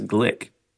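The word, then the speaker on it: Glück: poet laureate Louise Glick, rhymes with click.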